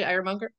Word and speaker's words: Ironmonger: And I practiced that, Ironmonger. That's what it is, right, Ironmonger? – Yeah.